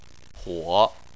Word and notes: huo3: chinese

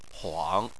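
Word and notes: huang: tone 3

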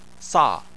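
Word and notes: sa: tone 4

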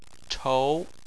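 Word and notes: chou2: tone 2

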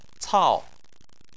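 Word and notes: cao: tone 4

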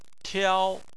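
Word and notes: tiao: tone 1

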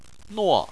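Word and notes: nuo4: tone 4

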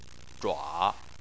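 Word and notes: zhua: tone 3

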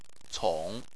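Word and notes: cong3: tone 3

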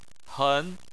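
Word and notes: hen: tone 2

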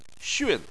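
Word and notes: xun: tone 4

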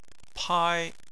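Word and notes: pai1: tone 1

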